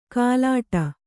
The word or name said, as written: ಕಲ್ಲೆಡೆ